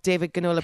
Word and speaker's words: David Ginola: David Ginola is how it's pronounced when I look up David Ginola pronunciation. So, David Ginola.